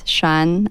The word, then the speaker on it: Shan: My name is Shan. I always spell my name because people don't know how it's pronounced or spelled, S-H-A-N.